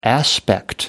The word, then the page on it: aspect: American English